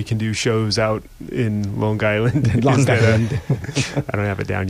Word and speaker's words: Long Island: You have to say Long Island. – It's Long Island? – One word. – Hard G.